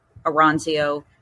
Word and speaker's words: Oronzio: my last name is spelled O-R-O-N-Z-I-O, first name Deborah, D-E-B-O-R-A-H, and I don't believe there are any other Deborah Aranzio persons out there on LinkedIn.